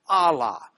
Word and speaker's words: Allah: It looks like, well, it looks kind of like a W, except the last tail on that W is going to the last letter, the H sound, the AH sound. So you've got A sound, LL sound and then an AH sound, Allah. The marks above are just kind of a vowel sounds and a guttural stop tells you how to pronounce it of sorts. So that's Allah in Arabic.